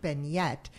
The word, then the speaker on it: beignettes: So it's spelled B-E-I-G-N-E-T-T-E-S, and it's pronounced beignet, and it basically means a diminutive beignet.